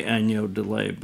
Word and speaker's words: agneau de lait: as the French say, agneau de lait, but I can't pronounce it very well. You did but very well.